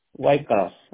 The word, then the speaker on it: Waycross: Ok, se escribe WAYCROSS, White Cross, así se escribe.